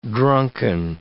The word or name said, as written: drunken